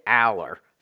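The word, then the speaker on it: Allar: Penn State put out a video of the kids who came in early, and Drew was one of them, and he pronounced it Aller. So that's what I, if he says it that way, that's what I'm going. That's with. my rule. I always go with what the guy says.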